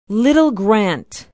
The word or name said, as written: little grant